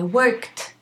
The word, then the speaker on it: worked: I worked. This is an ed ending, and we pronounce it as a t. Worked, worked. From work, work, worked. worked. The ED is a T.